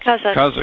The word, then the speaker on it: Kazakhstan: I can't even pronounce Kazakhstan. – It. – Kazakhstan.